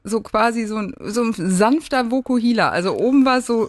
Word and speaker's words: Vokuhila: Vokuhila.